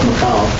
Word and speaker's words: Michal: He had animosity between his wives, particularly with his first wife, Michal, or Michael, as most people probably pronounce it,